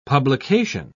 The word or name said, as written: publication